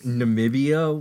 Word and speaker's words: Namibia: I think Namibia was one of them.